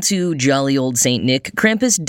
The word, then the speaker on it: Krampus: Or Krampus, if I were to pronounce it slightly more correctly.